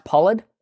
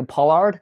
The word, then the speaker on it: Pollard: So it, because it's an Australian accent, but it's an English surname. So it's actually pronounced Pollard. Most people pronounce it in America Pollard.